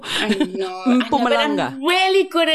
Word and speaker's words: Mpumalanga: And they are having the most spectacular time up at Mpumalanga. – Mpumalanga.